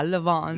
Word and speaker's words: Le Vent: Le Vent. – Mi suggeriscono la pronuncia. Le Vent.